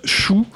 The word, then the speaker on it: Chou: Il y a un groupe, il y a un groupe de Power Violence d'Écosse qui, en français, se prononce chou, mais en fait c'est C-H-O-U.